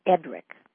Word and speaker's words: Edric: And one of my favorite stories was about Wild Edric, and I'd never heard of him. Wild Edric, or Edric the Wild. Am I pronouncing his name correctly? – Yes, yes, indeed.